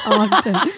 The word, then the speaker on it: Ogden: Ogden.